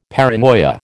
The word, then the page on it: paranoia: American English